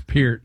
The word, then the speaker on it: Peart: It's Peart. – Yeah, Peart. – I've heard that. – I've heard him in an interview say it's Peart.